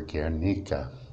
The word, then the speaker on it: Guernica: This is a poem called Another Gray Afternoon in Guernica, as we say in the U.S., or Guernica, as my Spanish-speaking friends tell me it's properly pronounced.